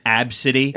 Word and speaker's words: ABCDE: Did you hear about the lady that's angry at Southwest because she named her daughter ABCDE, pronounced Absidy? – Absidy's a great fucking name, though.